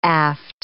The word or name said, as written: aft